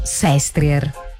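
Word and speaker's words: Sestriere: Anche se ti devo dire che noi in Piemonte diciamo Sestrier. In Italia dicono Sestrier.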